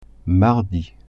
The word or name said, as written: mardi